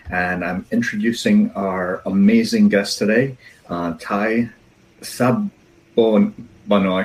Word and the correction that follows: Spano: It's all good. It's Spano. – Spano. Okay, perfect.